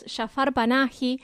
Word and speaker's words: Jafar Panahi: Ahí siempre se me mezcla la pronunciación, pero es Jafar Panahi,